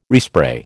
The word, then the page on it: respray: American English